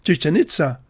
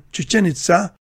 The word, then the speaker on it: Chichen Itza: Some people pronounce the name as Chichen Itza, but the Spanish pronunciation Chichen Itza, with the stress on the final syllables, is more consistent with the Mayan pronunciation.